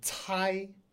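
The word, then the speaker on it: cai: So that would be tau and tai, tau, tai. So we talked about how C can be pronounced like the T-S in cats. Cats.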